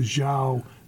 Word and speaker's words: Zhou: Talking about, I think it was called, pronounced the Zhou, Z-H-O-U dynasty.